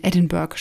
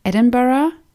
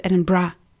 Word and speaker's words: Edinburgh: Allerdings hören es die Schotten tatsächlich nicht so gerne, wenn man von Edinburgh spricht. Sie selbst nennen es eigentlich Edinburgh. Und die geläufige Kurzform, wie es aber eigentlich die Ortsansässigen aussprechen, ist Edinburgh. Also da wird dieses Borough kurz gemacht. Eigentlich wird dieses Bra noch fast das R ein bisschen gerollt.